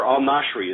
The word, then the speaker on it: al-Nashiri: In November, the Attorney General himself says al-Nashiri is the, or al-Nashiri is actually the correct pronunciation, al-Nashiri is the poster child case for why we should keep the military commission system alive.